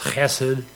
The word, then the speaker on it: chesed: Loving kindness is the Hebrew word chesed, is kind of hard to pronounce, chesed, C-H-E-S-E-D.